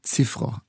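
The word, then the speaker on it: Cziffra: Georges Tschiffre war der Pianist in dieser Aufnahme aus den 50er Jahren. Die Aussprache des Namens variiert. Georges Tschiffre oder Tschiffre eigentlich korrekt.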